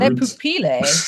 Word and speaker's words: Le pupille: Also, we've been saying it wrong because it's not French, it's Italian. – I don't know how to say words. – Le pupille. What if it's Italian? It's le pupille.